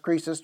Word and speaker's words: krisis: In the Greek, K-R-I-S-I-S, we'd spell it in, if we were spelling it in English, it's pronounced Croesus, 2920 in the Strong's.